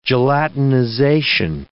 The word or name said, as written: gelatinization